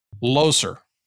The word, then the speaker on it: Loser: Loser. – Loser. Yeah, yeah. Right? – So it's some sort of German derivation of Loza. And when he got over here, it was Loser.